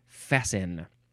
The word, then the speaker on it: fasten: Fast with an E-T, but you don't pronounce the T, you say fasten for some reason.